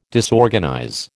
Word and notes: disorganize: American English

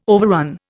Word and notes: overrun: Indian

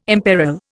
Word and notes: imperil: Indian

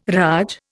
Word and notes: raj: Indian

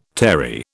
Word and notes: terry: British English

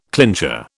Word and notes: clincher: British English

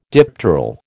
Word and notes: dipteral: American English

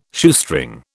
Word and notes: Shoestring: British English

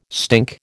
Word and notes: stink: American English

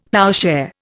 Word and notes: ploughshare: Indian

